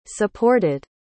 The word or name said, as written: supported